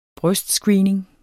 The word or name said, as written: brystscreening